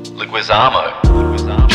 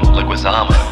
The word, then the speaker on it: Leguizamo: John Leguizamo. John Leguizamo. – So basically neither one of us pronounced it correctly in the first instance.